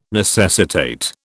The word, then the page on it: necessitate: British English